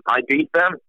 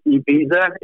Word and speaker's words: Ibiza: And we even ended up playing a show outside of the UK in Ibiza, or Ibiza, if I want to use the American pronunciation.